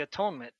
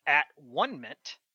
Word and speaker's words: atonement: And that should eat those things Were with the atonement, excuse me, I always say atonement because that's what I'm used to, but I've later learned that this word is made up. So I'm going to say it properly. And they shall eat those things wherewith the at one mint was made to consecrate and to sanctify them, but a stranger shall not eat thereof because they are holy.